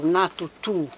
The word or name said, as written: na tutu